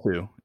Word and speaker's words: Hsu: I don't know how to pronounce HSU. – It is Claire Sue.